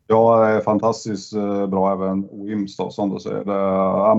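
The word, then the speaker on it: Wemyss: Och det är de som gör Weems, eller Weemys, säger vissa, men det ska uttalas Weems tydligen. – Det. – Enligt initierade källor. Nej, jag har hört det. Det var någon som korrigerade mig på det här en gång. Det ska vara Weems.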